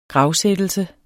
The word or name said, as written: gravsættelse